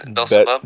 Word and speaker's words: Bezalel: But Beelzebub, or how do you say his name? – Bezalel. – Bezalel.